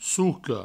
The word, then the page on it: soca: souco